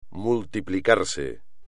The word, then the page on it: multiplicarse: espagnol